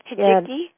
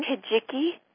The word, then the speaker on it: Hijiki: Hijiki, yes. – How is it pronounced again? – Hijiki. – Hijiki.